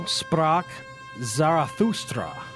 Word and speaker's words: sprach Zarathustra: Also, also sprach Zarathustra.